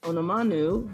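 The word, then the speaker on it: Onumonu: We have a tie between Abby Kim and then Ife Onamanu, who that's how you say it. Because it did not sound like that on the broadcast this week at all. It's a U, like, not an O.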